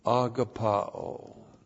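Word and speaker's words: agapaō: We saw it in 1 John, we saw it in 2 John, we saw it in 3 John, agapao. A-G-A-P-A long O. Agapao.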